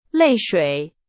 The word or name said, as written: 泪水